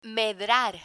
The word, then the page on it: medrar: Spanish